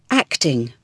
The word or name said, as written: acting